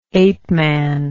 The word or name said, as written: apeman